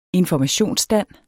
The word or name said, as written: informationsstand